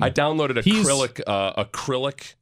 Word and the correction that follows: Cyrillic: Is that how you pronounce the Russian alphabet? Acrylic? Cyrillic? – Yeah, Cyrillic.